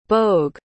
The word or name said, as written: bogue